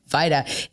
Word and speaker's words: Vaida: Vaida. It is not pronounced phonetically. It is a Hungarian name that my father-in-law decided would just be easier to pronounce if he changed the J to an I.